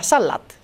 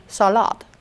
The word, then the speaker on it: sallad: Hon säger, istället för att säga sallad, så säger hon salad. Det är ingen som säger salad.